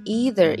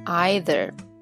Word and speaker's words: either: 首先呢，这个 either 这个词可以读成 either，也可以读成 either。那么在美国呢，这个 either 这个读音更常见，但是也有很多人会读 either。